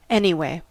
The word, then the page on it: anyway: American English